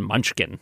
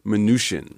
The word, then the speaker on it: Mnuchin: And I can tell you that officially the pronunciation is not Mnuchin, it's Mnuchin. – Like minutia. – Mm. As a wonderful email to Kai Rizdal once put it, the CH is soft as in champagne or charlatan.